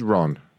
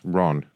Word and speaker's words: Ron: My name is Ron. – Ron. – Yes, Ron. R-O-N.